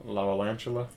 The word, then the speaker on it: Lavalantula: Lavalantula. – Lavalantula, and then two lava, two lantula.